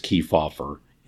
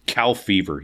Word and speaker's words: Kefauver: He would call Estes Kefauver intellectually dishonest and ignorant of the history of this country. Truman deliberately mispronounced his name. Cow fever, he called him.